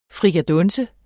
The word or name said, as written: frikadunse